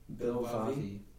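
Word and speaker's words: Bilvavi: Bil, B-I-L, Vav, V-A-V-I. – Bil Vavi.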